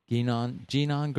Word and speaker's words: GNON: So we recently did a self-defense program for your GNON girl. Is that how you say it? – Correct.